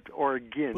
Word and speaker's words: Oregon: Yeah, it's pronounced Oregon, not like argon gas, but Oregon.